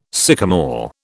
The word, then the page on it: sycomore: British English